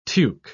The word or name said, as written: tuque